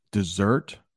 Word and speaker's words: dessert: So there's just a little difference on the way we pronounce those words. Dessert, desert. Dessert, desert.